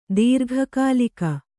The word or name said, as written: ದೀರ್ಘಕಾಲಿಕ